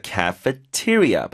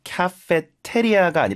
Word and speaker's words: cafeteria: A-N-N-E-X. 자, 그 도서관 별관에 보면 there is a cafeteria. 발음이요. Cafeteria가 아니라 T요, T.